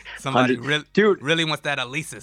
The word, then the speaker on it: Alesis: It's called Alesis, I think is the pronunciation. – Okay, yeah, I've heard of Alesis for sure.